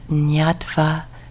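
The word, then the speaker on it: ñatvā: So you can hear that, nyatva, to know. And it's pronounced with the ny sort of sound in there. Item itang vise sato nyatva, etang vise sato nyatva, apamadam hi pandita, apamadam hi pandita, apamade pamuranti, apamade pamuranti, aryanango chare rat.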